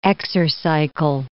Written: Exercycle

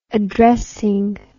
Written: addressing

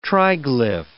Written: triglyph

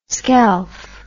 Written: skelf

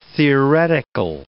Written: theoretical

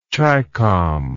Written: chicom

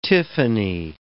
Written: tiffany

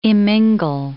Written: immingle

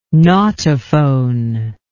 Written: nautophone

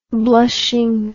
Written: blushing

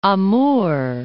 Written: Amur

